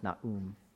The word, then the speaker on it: Nahum: It's actually pronounced Na'um.